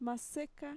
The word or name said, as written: maseka